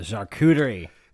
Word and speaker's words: charcuterie: The charcuterie.